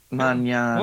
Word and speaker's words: Mañana: How do you spell Mañana? – M-A-N with the tilde over the top. – Squiggly thing. – Mañana. – Well, then, hey, you know what? Then I pronounce it properly.